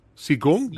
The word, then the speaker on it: Qigong: He says, thank you, sir, for showcasing my Qigong. Did I say it right? – Yeah. All right. Close enough.